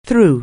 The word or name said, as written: through